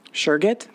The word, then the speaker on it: Surget: S-U-R-G-E-T. – Surget? – Surget.